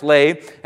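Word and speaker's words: lave (לב): He's talking about, the Hebrew word is actually lave, and it's from a Hebrew word, L-E-B, spelled lay or pronounced lave.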